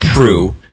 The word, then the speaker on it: Proulx: And that's spelled P-R-O-U-L-X, and it's pronounced Proulx.